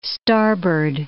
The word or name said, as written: starboard